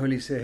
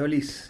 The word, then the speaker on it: Olise: No es Olise, es Olise. – Bueno, él en una entrevista la otra vez dijo que por ahí ya no dice nada. Que... – Pero no tiene acento en la E, por eso la última E no se pronuncia.